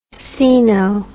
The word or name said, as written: caeno